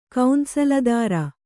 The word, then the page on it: ಕೌಂಸಲದಾರ: kannada